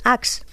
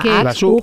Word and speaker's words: UGG: Las botas estas que se llaman Axe, que se escribe UGG, porque sí, hay quien las llama Axe.